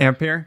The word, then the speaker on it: Ampere: Ampere? – There you go. How he's pronouncing it. – So. I think in reference to like amp in electronics, I think.